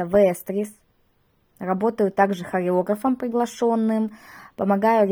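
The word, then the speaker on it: Vestris: Вестрис — ударение итальянское. На итальянском языке очень часто именно на последний, на второй слог ударение. Для нашего русского менталитета все-таки чаще ударение спереди, поэтому вот здесь такая немножко игра слова, и на русском нам удобнее все-таки слышать и читать Вестрис.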